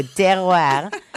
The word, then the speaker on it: terroir: It has terroir. – Oh, wow. French girl. Tell us what that actually means. – Oh, yeah. Okay, so terroir. I'm saying it with the French accent.